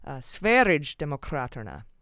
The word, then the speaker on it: Sverigedemokraterna: And for the pronunciation of the right-wing party in Sweden, I found out I was close, but it's a soft G, like GIF. It's not GIF. It's GIF. And that makes it Sverigedemokraterna.